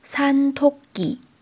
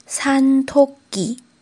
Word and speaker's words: 산토끼: 名字叫做野兔子，用韩文来说是 산 토 기， 산 토 기，它其实是两个韩文单词的合成， 산 山， 토 기 兔子。